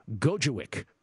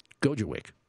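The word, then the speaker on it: Gojowczyk: The name, it's spelled a lot harder than it's actually pronounced. Gojewicz, which is G-O-J-O. Really, all you have to remember is Gojo. Not Flojo. Gojo. And the last part of his last name is spelled W-C-Z-Y-K. But don't worry about it. It's Gojo. It's Gojewicz.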